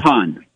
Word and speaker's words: Beaughan: By the way, what's the pronunciation on the spring? A lot of letters in there. – Bohan. – I'm sorry? – Bohan. – Bohan. Oh, there, I didn't even think of that one. B-E-A-U-G-H-A-N for listeners.